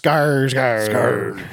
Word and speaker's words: Skarsgård: Oh, and Dave, you did pronounce Bill Skarsgård's name wrong. It's Skarsgård.